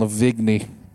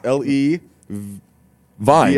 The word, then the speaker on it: Levine: Adam Levigny. – That's not how his name is spelled. It's L-E Vine. – V-I-N-E. – Levine.